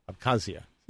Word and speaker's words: Abkhazia: Abkhazia. – Mm-hmm. – Abkhazia. – Abkhazia. That's good. – I broke these up into two different syllables here accidentally.